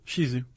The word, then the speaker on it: Shih Tzu: I always said Shih Tzu. Is it Shizu? – Shizu.